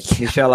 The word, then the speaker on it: Michel: Not Michael Bay. Michel Ansel.